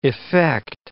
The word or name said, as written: effect